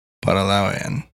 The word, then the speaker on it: Para Lao Aeon: The emphasis is on the Lao. Para Lao Aeon.